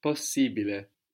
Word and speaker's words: possibile: So A-B-L-E becomes A-B-I-L-E, and I-B-L-E becomes I-B-I-L-E. – Abile, ibile. – So possible becomes Possibile, possibile. – But we also pronounce the two S's. – Possibile.